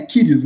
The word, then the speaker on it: Kyrgios: Agora, o, Renan, o Nick Kyrgios, é assim que fala o nome dele? Eu falei errado. É, Kyrgios, né? Kyrgios.